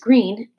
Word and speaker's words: Greene: Once upon a time, 8 years ago, a man and a woman named Mr. and Mrs. Greene, that's Greene with a silent E at the end, discovered that they were expecting a baby girl.